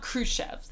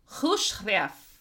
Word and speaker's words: Khrushchev: It's not Khrushchev. That's the American version of it, right? It's... KH is pronounced like a hard H and roll the R. So Khrushchev. – No. Khrushchev.